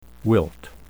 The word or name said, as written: wilt